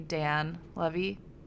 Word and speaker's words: Dan Levy: I would say that my Christmas crush of the week was definitely Dan Levy.